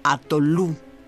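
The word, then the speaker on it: atolu: La palabra atolón proviene del Divey, una lengua indoaria hablada en las Maldivas, y se pronuncia como atolú. El primer registro escrito de esta palabra es de 1625.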